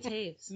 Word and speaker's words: Toews: Miriam Taves. – Miriam Taves. Fight Night. – But it's not spelled that way, but... – T-O-E-W-S. – Yeah. – Is how you spell it, but it's pronounced Taves.